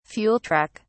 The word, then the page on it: fuel truck: British English